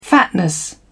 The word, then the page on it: fatness: British English